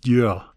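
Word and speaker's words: Győr: Und die Stadt spricht sich Dyör, weil das GY ist ein DJ.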